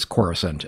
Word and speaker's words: Coruscant: Dictionary pronunciation is a little bit different, but the spelling of the word supports Coruscant as a name.